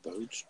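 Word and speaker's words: Boetsch: Botch? Is that how you say his name? – Botch.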